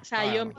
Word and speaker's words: Xiaomi: Xiaomi, Xiaomi, Xiaomi, Xiaomi.